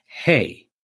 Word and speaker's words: hei: We're going to use the sound power, hey, just like the food that horses and cows eat, except it's spelled H-E-I, hey.